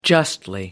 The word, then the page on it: justly: British English